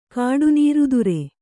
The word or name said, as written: ಕಾಡುನೀರುದುರೆ